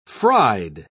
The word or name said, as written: fried